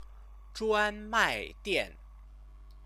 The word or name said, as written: zhuanmaidian